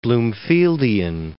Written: Bloomfieldian

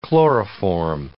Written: chloroform

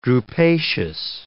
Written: drupaceous

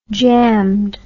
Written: jammed

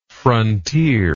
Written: frontierlike

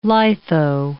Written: litho

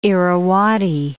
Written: Irrawaddy

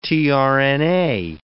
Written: tRNA

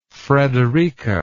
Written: frederica

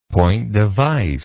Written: point-device

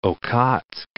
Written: Okhotsk, Sea of

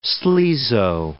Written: sleazo

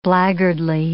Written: blackguardly

